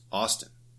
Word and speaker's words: Auston: But it's pronounced Austin, not yeah. Austin. – Well, I know, but he spells it like Austin, right? – Right, like Gaston, but Austin.